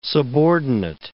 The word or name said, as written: subordinate